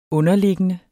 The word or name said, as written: underliggende